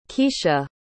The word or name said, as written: keshia